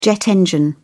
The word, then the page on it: jet engine: British English